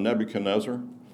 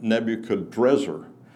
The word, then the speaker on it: Nebuchadnezzar: Another example is king of Babylon, Nebuchadnezzar. Well, that's how you pronounce his name in Hebrew. In Aramaic, you pronounce his name Nebuchadrezzar, where R's and N's flip-flop.